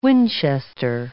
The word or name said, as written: Winchester